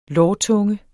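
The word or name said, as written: LÅRTUNGE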